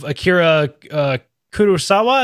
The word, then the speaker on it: Akira Kurosawa: So you kind of talked about your Japanese descent impacting work throughout your life, and you've specifically mentioned the work of Akira Kurosawa.